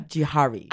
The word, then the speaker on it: Jouhari: Can I say it right? – Yes. – Mitra Jouhari. – Mitra yeah. Jouhari.